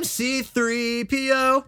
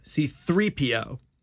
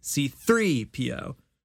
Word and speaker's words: C-3PO: I am C-3PO. That's not how you say it, though. C-3PO. So the accent is on 3. C-3PO.